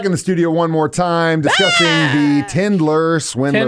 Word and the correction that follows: Tinder Swindler: Before, I keep saying it wrong. Tinder Swindler.